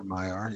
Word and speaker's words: Maiar: But Radagast is one of the Maiar, right? Is that how you pronounce that? M-A-I-R? – Yes, Maiar. Yeah, Maiar, sure.